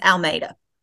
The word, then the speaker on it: Almeida: It's Almeida.